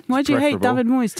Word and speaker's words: Moyes: It's David Moyes, isn't it? – Moyes, correct pronunciation.